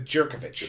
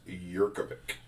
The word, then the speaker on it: Yurkovich: Is that J.A. Soft J? Yurkovich? Or is it Jerkovich? – It's Yurkovich.